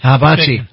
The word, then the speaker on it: hibachi: Hitachi, am I saying it wrong? – Hibachi. – Hitachi.